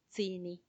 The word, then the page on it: tsini: Macuiltianguis